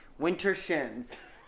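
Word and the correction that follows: Widdershins: No, it's Wittershins. – He's just describing. – You know how people in Lancaster say water? Witter.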